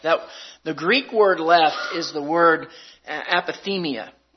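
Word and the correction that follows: aphiemi (ἀφίημι): I'm sorry, aphiemi. I said that wrong.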